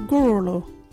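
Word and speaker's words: gurulhu: Our local Wajiri word is Gurulu.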